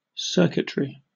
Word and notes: circuitry: British English

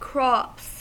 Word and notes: crops: American English

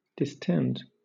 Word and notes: distend: British English

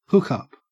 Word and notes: hookup: Australian English

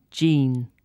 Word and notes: jean: British English

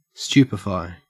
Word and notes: stupefy: Australian English

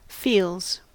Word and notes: feels: American English

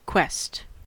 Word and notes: quest: American English

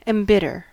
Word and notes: embitter: American English

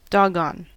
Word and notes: doggone: American English

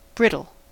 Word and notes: brittle: American English

